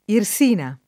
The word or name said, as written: Irsina